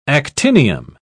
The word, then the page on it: actinium: British English